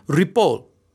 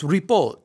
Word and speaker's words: Rapol: It's really Rapol. It's Rapol. And many people think that it's Italian. Oh, Ripoli, Ripolini, Ripoli. And there's no vowel at the end. There's no I.